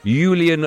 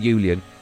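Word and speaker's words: Julen: Now, ahead of Wolves against Fulham, live on TalkSport in the Premier League tonight, the Wolves manager, Julian Lopetegui, not Julian, that's how you say it, Julian, has been speaking as his side look to bounce back from a disappointing defeat last time out at home to Bournemouth.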